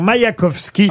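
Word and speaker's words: Maïakovski: Je vous l'accorde parce que je pense qu'on peut le prononcer ainsi. Vladimir Maïkovski.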